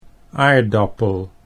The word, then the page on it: aardappel: Dutch